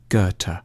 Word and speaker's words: Goethe: A safe bet is to start with a hard G on Ge and end with a T, Goethe.